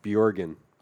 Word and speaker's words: Bjorgen: How do you say his last name? – Bjorgen. – Bjorgen. – Yeah. Yeah, Google him. – But figure out how to spell it on your own. – B-J. – But even his first name. – Yeah, Chell. I mean, yeah, it's a nightmare. – K-J or something? – K-J-E-L-L-B-J-O-R-G-E-N. I think that's right. – That sounds right. – I gave myself a B-J-O-R-N one time.